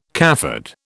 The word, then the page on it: cafard: British English